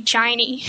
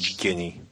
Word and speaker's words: Ginny: I was thinking a hard G. I didn't think Ginny.